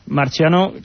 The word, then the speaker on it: Marciano: Marciano, claro, pronunciado a la italiana porque es marciano,